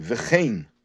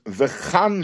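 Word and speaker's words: vechein (וְכֵן): So the Rebbe says that the word vechein we pronounce with the vowel of tzeirei, vechein, and not bepasach with the vowel of pasach, which is vechan shnei k'suvim.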